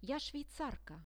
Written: ja swisska

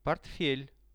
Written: portfel

